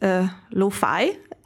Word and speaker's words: Lufay: So first of all, it's pronounced Lufay. And it's kind of like how you would write it down in Italian, the word lo-fi, so like low fidelity,